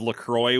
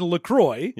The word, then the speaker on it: LaCroix: You'd think, I bought like a fucking pallet of LaCroix or La Croix or whatever. I know you're not supposed to pronounce it like it's a French word because it's not.